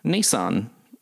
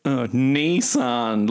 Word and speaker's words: Nissan: Which in Australia we pronounce Nissan. – Yes. – But I was thinking about it, and because it's Japanese, it's literally two characters, Ni and San. – Mm-hmm. – So Americans have been saying it right, but like I've been laughing about it my whole life.